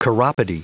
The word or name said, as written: chiropody